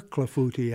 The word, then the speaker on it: clafouti: Clafouti is C-L-A-F-O-U-T-I. – C-L-A-F-O-U-T-I.